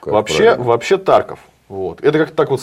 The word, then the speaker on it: Тарков: Тарков, Краков, есть примерно подобные как бы названия городов, которые звучат вот так вот, с ударением на первый слог. Потому что иначе как-то звучит неестественно.